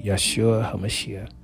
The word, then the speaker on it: Yeshua HaMashiach: Okay, that's in Hebrew. That's how you say it in Hebrew. Yeshua HaMashiach. HaMashiach is the Christ, the Messiah, the anointed one.